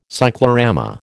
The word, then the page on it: cyclorama: American English